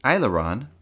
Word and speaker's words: aileron: Aileron rhymes with Sailor John and comes from French.